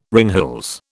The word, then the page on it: ringhals: British English